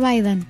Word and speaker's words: Biden: ¿Cómo se dice Biden? – Joe Biden. – Biden, Biden.